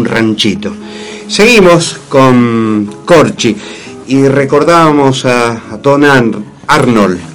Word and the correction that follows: Andor: No, Arnold. Andor. – Andor. – A-M. – Pero esta Andor. Pronunciación, che.